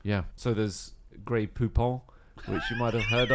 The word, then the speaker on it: Grey Poupon: Grey Poupon. – Grey Poupon. To rhyme with coupon.